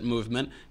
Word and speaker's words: MVMT: MVMT, but we pronounce it Movement, because look, we don't have time for all those vowels.